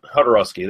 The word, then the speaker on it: Jodorowsky: I can't even pronounce his fucking name. The guy who directed El Topo. – Jodorowsky, there you go.